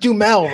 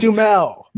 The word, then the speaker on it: Duhamel: He was really using Reigns in all these videos more than anyone else, more than Mark Wahlberg, more than Josh Duhamel. I can never say it. – It's just Duhamel, Raj. It's pronounced Duhamel.